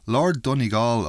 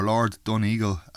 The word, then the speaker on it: Donegal: This one is Sarah and Rita Keane of Carlistrand, County Galway, singing a version of the song called Lord Donegal, or Lord Doneagle, as they pronounce it.